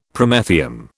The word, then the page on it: Promethium: British English